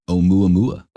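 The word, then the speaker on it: Oumuamua (ʻOumuamua): Oumuamua.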